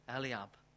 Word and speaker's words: Eliab: You know, we read also, how do you pronounce David's oldest brother? Eliab. Eliab, David's oldest brother.